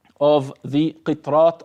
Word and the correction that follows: Qirat: of what I mentioned. – Qirat. – I keep saying it wrong, Shaykh. I'm not sure why. – Qirat.